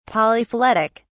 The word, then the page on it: polyphyletic: British English